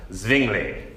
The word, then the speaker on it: Zwingli: You could say it's S-W-I-N-G-L-I, but he was a Swiss German, and so he's pronounced it Zwingli.